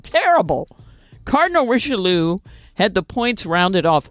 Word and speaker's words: Richelieu: Cardinal Richelieu, is that how you say it? – Oh, yes, that's right.